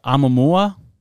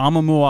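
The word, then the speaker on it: Oumuamua (ʻOumuamua): I think the Oumuamua object, perfect. – The Oumuamua object.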